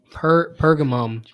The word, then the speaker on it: Pergamum: Pergamo, is that how you say it? – Pergamum, I think.